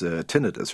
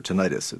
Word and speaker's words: tinnitus: It was tinnitus, or tinnitus. It's pronounced both ways.